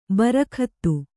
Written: ಬಾರುಗುತ್ತು

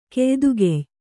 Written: ಕೆಯ್ದುಗೆಯ್

